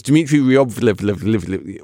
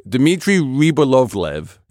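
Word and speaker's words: Dmitry Rybolovlev: It's Dmitry Ryobolovlev. Wait, what? Okay. Dmitry Ryobolovlev.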